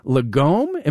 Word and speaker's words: lagom: Lagom, am I saying that right? – Yeah.